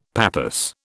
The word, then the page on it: pappus: British English